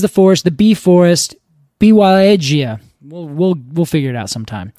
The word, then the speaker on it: Białowieża: Białowieża. That's how I pronounce it. That's how I believe it's pronounced. – Did you find a pronunciation guide for this? – Yeah, okay. Białowieża. And edge is all capitalized, so I think that's where the emphasis should go.